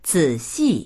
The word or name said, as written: zi3xi4